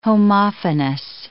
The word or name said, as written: homophonous